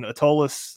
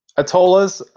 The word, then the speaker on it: Atollas: We're going to be talking Atollas. Did I pronounce that right? – Atollas.